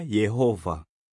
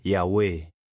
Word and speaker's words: Yehova: Jina hilo linatamkwa kwa njia tofauti katika kila lugha. Katika Kiswahili, mara nyingi linatamkwa Yehova. Lakini watu wengine wanalitamuka Yahweh.